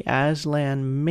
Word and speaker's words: Aslan: It's spelled A-S-L-A-N. Yes, just like the Aslan in Chronicles of Narnia.